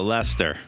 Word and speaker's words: Leicester: I'm pretty sure that you pronounce what looks like Leicester. If you're in England, you call it Leicester.